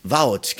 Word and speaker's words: Wout: Wout. – Wout, genau, Wout.